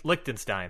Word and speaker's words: Liechtenstein: Liechtenstein.